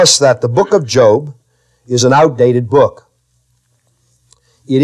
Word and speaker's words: Job: The story of Job, or as he's called by the uninitiated, Job, the book of Job, his name is Job, it's a long O, deals with the problem of man's trials and tribulations and troubles and his sufferings, his difficulties.